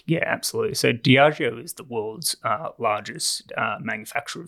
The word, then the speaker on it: Diageo: And I pronounce it as Diageo. I think that's how it's pronounced.